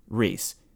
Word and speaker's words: Reese: Reese. Like Reese Witherspoon. Reese.